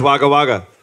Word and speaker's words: Wagga Wagga: Yeah, I know I said it wrong. I don't give a shit. – What? – Not anymore. No, not anymore. Not anymore. Yeah. Not anymore. Now it's Wagga Wagga.